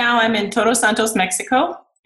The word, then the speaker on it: Todos Santos: Todos Santos.